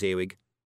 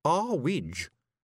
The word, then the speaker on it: Earwig: It's too high, Mrs. Earwig, said Tiffany. It's pronounced Arwidge, said Mrs. Earwig coldly.